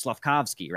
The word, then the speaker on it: Slafkovsky: Now you're going to add a Logan Cooley, a Slafoski. I always mispronounce that name, but you know who I'm talking about. – Yeah. – That's going to be dangerous. Slafkovsky, rather.